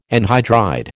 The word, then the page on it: anhydride: American English